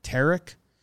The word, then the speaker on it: Tarik: But Tarik I've like Derek. – Really? – Yeah. – Yes. – So I've been Tarik wrong Skubal my entire life. Tarik. Like Derek? Tarik?